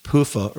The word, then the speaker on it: PUFA: Her recipe is actually called coconut olive oil mayonnaise, and it's also, I'm gonna pronounce this PUFA, or P-U-F-A, PUFA free, and PUFA stands for, let's see, let me get the correct before I...